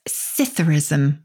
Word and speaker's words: psithurism: The second is one I think a lot of people will have heard of, and that's Citherism with a silent P. Citherism. – The P is silent, as in swimming pool.